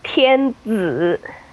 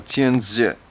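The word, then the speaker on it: Tianzi (天子): It is Tianzi. – I'm sorry, Tianzi? – Yes. Okay. The Z is sort of a TS, sort of a Z sound. – Okay. So Tianzi.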